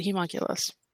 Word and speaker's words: homunculus: Thank you, Twy. I appreciate it. That's how you pronounce it, humunculus.